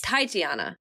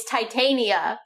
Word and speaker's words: Titania: Titiana. – No, it's Titania. – Oh, my God. – We got it totally wrong. It's Titania.